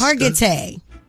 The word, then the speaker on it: Hargitay: Hargitay.